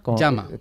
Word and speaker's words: Llama: Llama, Llama. – ¿Cómo se escribe? – Llama. Como las llamas de los Andes, LLAMA.